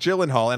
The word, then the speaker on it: Gyllenhaal: Um, but with Mr. Uh, Gyllenhaal, am I saying that right? Jake Gyllenhaal? Gyllenhaal? It, yeah, yeah.